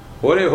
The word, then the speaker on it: Hare: Hare, Hare. Hore, Hore. In Bengali, we'll say Hore, Hore. Somebody will say, I have some kirtans, but they will not say Hare, they Hare. Hare Krishna. They cannot pronounce Hare.